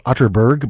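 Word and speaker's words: Atterberg: It's spelled Otterberg, but pronounced Otterberg, just in case you are Googling.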